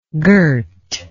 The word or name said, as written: gert